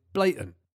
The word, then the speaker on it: blatant: I grew up in the southeast of England, and we'd always drop the T in the middle and pronounce it blatant, and say things like, that was blatantly obvious.